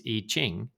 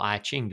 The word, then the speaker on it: I Ching: which for those who know the ancient Chinese I Ching or I Ching, depending on how you say it, it's...